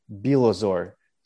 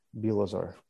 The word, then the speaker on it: Bilozor: My real last name is Bilozor. So it's Bilozor. Bilozor.